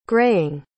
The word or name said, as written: graying